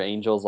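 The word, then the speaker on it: Angell: So one thing, first off, it seems like there's a clear consensus, including among the Angel family, that Roger Angel's last name is pronounced Angel.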